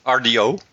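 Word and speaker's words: Rdio: Als we dus Spotify en radio, of hoe we het dan ook gaan noemen, als RDO. Zo spreek je eruit.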